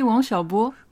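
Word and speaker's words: Wang Xiaobo: Oui, Wang Xiaopo, qui tu bien prononcé.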